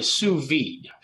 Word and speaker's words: sous vide: So it's called a sous vide. – Sous vide. So I kept saying it's a sous vide. – Yeah, it's spelled not like it sounds.